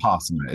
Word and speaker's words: Vipassana: Vipassana? Mm-hmm. Is that the right way to say it? – Vipassana.